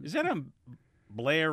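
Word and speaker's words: Belair: Beth says, in case you're wondering how Blair is spelled, it's B-E-L-A-I-R. I was giving the Baltimore pronunciation.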